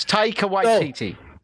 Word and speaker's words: Taika Waititi: Taika Waititi. – Taika Waititi. I just knew that you would know how to say his name. – Right? Yes, Taika Waititi.